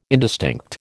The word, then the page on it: indistinct: American English